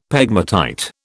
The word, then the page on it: pegmatite: British English